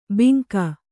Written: ಬಿಂಕ

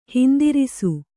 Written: ಹಿಂದಿರಿಸು

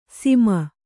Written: ಸಿಮ

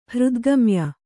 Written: ಹೃದ್ಗಮ್ಯ

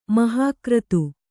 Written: ಮಹಾಕ್ರತು

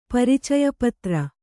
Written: ಪರಿಚಯಪತ್ರ

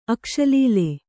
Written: ಅಕ್ಷಲೀಲೆ

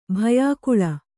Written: ಭಯಾಕುಳೆ

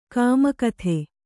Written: ಕಾಮುಕತೆ

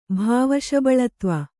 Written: ಭಾವಶಬಲತ್ವ

